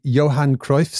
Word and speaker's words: Johan Cruyff: And there's another one at now I'm possibly going to pronounce this wrong, the Johan Cruyff Stadium.